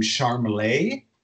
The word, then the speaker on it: Charmless: And so like the idea was that his parents had named him Charmelet, like in the French, like with a French pronunciation, but it's actually, but Yangus in particular always read it Charmless. And other people around, and he's like, no, it's Charmelet. But it's spelled Charmless.